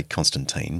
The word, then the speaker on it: Constantine: One thing I try to make clear in the book is that Constantine, when he became a Christian, I know you Americans say Constantine, but in the British Australian tradition, it's Constantine.